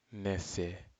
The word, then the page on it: nese: Aneityum